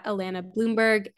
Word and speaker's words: Allana Blumberg: And then you can find me on Instagram, TikTok, and YouTube under at Alana Bloomberg, A-L-L-A-N-A-B-L-U-M-B-E-R-G.